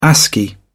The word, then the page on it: ascii: British English